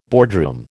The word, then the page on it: boardroom: American English